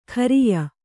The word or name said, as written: ಖರಿಯ